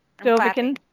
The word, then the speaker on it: Dovahkiin: Dovahkiin.